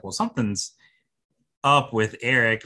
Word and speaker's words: Erek: His name is Eric, and it's spelled E-R-E-K, which I thought was a very unique, cool way to spell Eric.